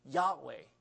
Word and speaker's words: Yahweh: So our best guessed Hebrew or English pronunciation of this name, YHWH in the Hebrew letters, is Yahweh. You've heard of this name, Yahweh. Yahweh.